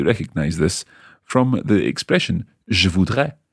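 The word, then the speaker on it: je voudrai: Notice the pronunciation there, je voudrais, A-I at the end, as opposed to je voudrais, A-I-S.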